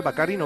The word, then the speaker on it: Baccarin: ¿Cómo se dice? ¿Baccarin o Baccarin? – Baccarin. – No sé. Morena Baccarin es, ¿no? No Baccarini, sí. No, Baccarin, Baccarin, exactamente.